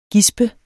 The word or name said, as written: gisp